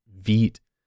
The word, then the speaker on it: Vite: Until I looked into Hydrogen, it's called Vite. It's spelled V-I-T-E.